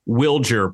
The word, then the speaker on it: Wilger: Wilger Abreu has confirmed once and for all that he does prefer Wilger pronunciation here in the States. So we will go with that.